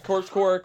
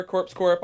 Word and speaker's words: Corpse Core: It's Corpse Core, correct? – Yes, Corpse Core, Corpse Corp. Either pronunciation's fine.